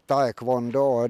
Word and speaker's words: Taekwondo: Ni representerar Tenala-föreningen Tornados ITF Taekwondo.